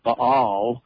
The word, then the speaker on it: Baal: Some people pronounce it properly, Baal, right back to some of the traditions we have with us today.